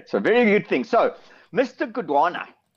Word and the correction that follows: Godongwana: Godongwana, I must pronounce it correctly.